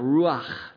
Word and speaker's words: ruach: It can't be pronounced without breathing out. Ruach, the had sound.